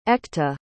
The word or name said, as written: Ekta